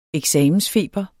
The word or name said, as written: eksamensfeber